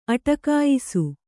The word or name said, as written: ಅಟಕಾಯಿಸು